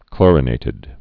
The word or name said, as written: chlorinated lime